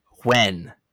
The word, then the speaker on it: Nguyen: There's a time where here, PSA from Peaches Brittany, the last name NGUYEN. – When? – It is pronounced when, like, when are we going to the store? Not Nguyen, as the narrator said throughout the entire book, because that was one of the bad guys that you saw a lot.